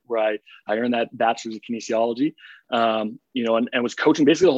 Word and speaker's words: kinesiology: Kinesiology. Is that? – Pretty close. Kinesiology. Yeah.